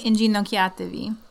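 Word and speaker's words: inginocchiatevi: Um, Venite in in gin in gino uh, ah, I can't even pronounce it. In ah, gino invite yes. in ginocchiatevi. That one.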